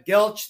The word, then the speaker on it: Geltch: No, Geltch, that is correct.